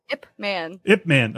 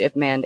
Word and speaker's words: Ip Man: Eat sushi, swim class, and fight IP Man. What's that? – Ip Man. – Oh, sorry. That's how it's pronounced? I've been saying IP Man this entire...